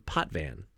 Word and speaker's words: Potvin: His name was Felix Pot, P-O-T-V-I-N. But they called him Potvan. Was like his, that's how they pronounce his name, the announcers.